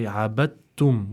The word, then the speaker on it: عبدتم: That means we don't pronounce the dal when reciting this word, and we instead say abattum. We go straight to the ta, which has a shadda on it, and pretty much skip the dal in terms of the way that we pronounce it.